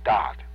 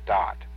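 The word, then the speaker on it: Daath: It is called Dot. And it's spelled D as in David, A-A-T-H, D-A-A-T-H. And remember that a T-H is pronounced with as a hard T, so it's Dot.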